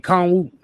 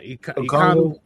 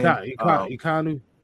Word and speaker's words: Ekwonu: Oh, Akeem Ekwonu, not Ekwonu. – That's how you say his name. – Ekwonu.